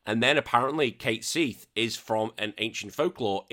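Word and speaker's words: Cait Sith: And then recently, as of like the past few months, I've only found out it's actually meant, well, originally it's meant to pronounce Kite Sheath. I was like, fuck, what? – But honestly, it's spelled S-I-T-H.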